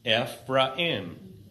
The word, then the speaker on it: Ephraim: It's Ephraim.